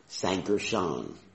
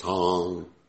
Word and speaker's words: Sankarshan: It's not Sankarshan. You know, just teaching devotees the right pronunciation. It's Sankarshan. Got it? So next time you pronounce this name, don't say Sankarshan. That actually would be the Pali pronunciation of the word. But it's Sankarshan.